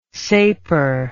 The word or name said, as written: sapor